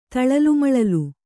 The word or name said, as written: ತಳಲುಮಳಲು